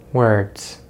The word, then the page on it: words: American English